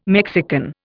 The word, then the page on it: Mexican: Indian